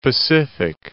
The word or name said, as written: Pacific Islands, Trust Territory of the